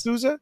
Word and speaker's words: Sousa: Sousa? Because like a sous chef.